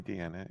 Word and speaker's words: Deanna: Also, you can call me Deanna, either one. I'm Piranha Plant on Twitter. Spelled the. Screw it. I'm not even going to try to explain how it's spelled with A-N-N-A, like Anna. But anyway,